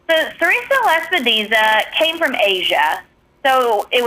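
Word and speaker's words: sericea lespedeza: So our next topic here, and I'll let you pronounce it because, again, I Serratia Lepidesa. I can't control. I still think I butchered that. – Serratia Lepidesa.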